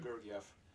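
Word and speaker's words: Georgiev: I think it's Gordiev. – Is it Gordiev? – Yeah. It's I heard Gordiev. – Gordiev? – Via announcers.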